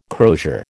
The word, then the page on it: crosier: American English